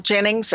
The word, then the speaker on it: Ginnings: Jennings. And my last name is spelled G-I-N-N-I-N-G-S, just like a cotton gin.